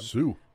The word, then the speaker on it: Sioux: And the most interesting one I've seen is actually Sioux City, Iowa. Their airport three-letter identifier is SUX. So I'll let you say that one out loud. – Sioux.